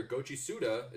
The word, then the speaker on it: Goichi Suda: Director Goichi Suda, is that how you pronounce his name, Jonathan? – Mm-hmm.